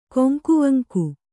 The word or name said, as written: ಕೊಂಕುವಂಕು